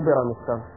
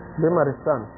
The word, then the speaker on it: بيمارستان: شوفوا وصف البرامستانيات. أنا قاعد أنطقها غلط ترى. بيمارستان هي مو بيرامستان. بيمارستان.